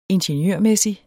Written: ingeniørmæssig

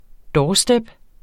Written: doorstep

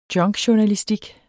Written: junkjournalistik

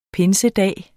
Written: pinsedag

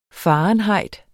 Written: fahrenheit

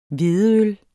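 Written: hvedeøl

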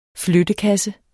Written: flyttekasse

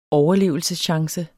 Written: overlevelseschance

